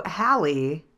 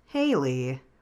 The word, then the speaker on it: Hallie: So Hallie, not Haley, which makes sense because her name is spelled with two L's, but my brain did not recognize the second L. – Okay, the only reason why I know it's Hallie is because I listened to it on audiobook.